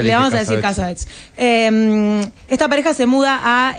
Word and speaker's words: Cassavetes: ¿Cómo se pronuncia Cassavetes en Estados Unidos? – Cassavetes.